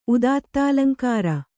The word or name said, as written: ಉದಾತ್ತಾಲಂಕಾರ